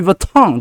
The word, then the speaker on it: Vuitton: 他说你们不是踢到脚会讲好痛吗？对不对？你把它套进去就可以啊。哦，Louis Vuitton 好痛啊，Louis Vuitton 的的痛就可以，对不对？